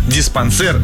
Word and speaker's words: диспансер: Ну и, конечно, далее у нас был «диспансер». «Диспансер». – «Диспансер» вы сказали. Правильно.